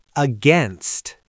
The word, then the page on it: against: American English